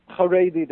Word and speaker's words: Haredi: it's Haredi, is that how you say it? – Haredi, they say God-fearing, yes.